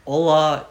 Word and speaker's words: hola: Hola is hello in Spanish. Hi. Hi, hello. They just say hola, which is starts with a H, but the H is silent.